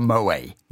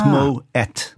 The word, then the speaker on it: Moët: Actually, I think one of the first things I ever learned on this lot was that it wasn't called Moe. – Yes. – Because a lot of people still probably call it that. You do pronounce the T. – And well, there's the little two dots over the E. So you would that makes a two syllable word. So it's Moët.